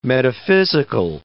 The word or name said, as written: metaphysical